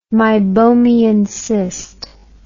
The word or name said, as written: meibomian cyst